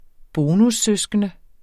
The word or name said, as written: bonussøskende